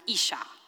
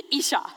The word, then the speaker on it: isha: The Hebrew word here for woman is a word pronounced isha, I-S-H-S-H-A-H. Okay, isha, that's how you pronounce it.